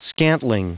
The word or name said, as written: scantling